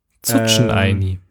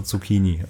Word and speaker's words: Zucchini: Und dann macht die korrekte Aussprache Zucchini.